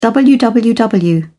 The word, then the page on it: www: British English